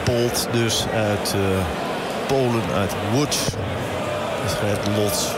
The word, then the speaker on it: Łódź: De L met zo'n schuin streepje erdoor. Die bestaat in het Nederlands niet, die letter. Maar ja, dat spreek je uit als een V. – Oké. Okay. – Dus, ja, en dan... dan wordt het niet Watts, maar het wordt dus Łódź. Ongeveer.